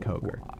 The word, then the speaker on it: Coker: But tomorrow at 10 a.m., they are playing a school called, I hope I'm pronouncing this right, Coker? – Yes. That is how I would pronounce that, Coker.